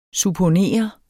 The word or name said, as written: supponere